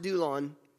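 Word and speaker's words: doulos: This is doulon, and it's spelled with an S. There's not a typo there, but it's pronounced doulon. We belong to God as his bond servants.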